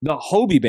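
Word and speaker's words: Hobey: Is it the Hobie Baker? My bad. Not Hubie. Hobie Baker Award.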